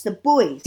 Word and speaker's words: Buoys: It's spelled B-U-O-Y, right? The Buoys or Boys? – B-U-O-Y. Yes, The Boys.